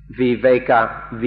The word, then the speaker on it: viveka: In Pali, the word is viveka, V-I-V-E-K-A, or viveka. And in Thai, it's pronounced we wake.